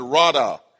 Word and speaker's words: radah: Dominion there, that word dominion there is the word radah. In the Hebrew, it is spelled R-A-D-A-H, R-A-D-A-H.